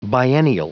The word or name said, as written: biennial